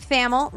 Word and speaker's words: Thamel: Pete Thamel, rhymes with camel, and our friend Pat Forde.